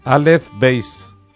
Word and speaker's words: Aleph Beis: Aleph Beis. – Alfabeto hebreo, Aleph Bet en pronunciación yiddish.